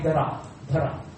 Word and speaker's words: Dhara: And his wife was actually Drona and Dhara. D-H-A-R-A, long A.